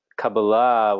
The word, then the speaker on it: Kabbalah: And so the esoteric dimension of Torah is typically known by this term Kabbalah, or Kabbalah, depending on the pronunciation. Kabbalah, which is the traditional pronunciation of the Hebrew, has become more popular in New Age circles in probably the last decade.